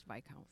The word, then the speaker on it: Viscount: I think it's Viscount. I know there's an S in it. – Oh, Viscount. – I think it's pronounced Viscount.